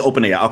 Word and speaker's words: OpenAI: It's just OpenAI. Okay. – Yeah, like a compound, like OpenAI, like two words.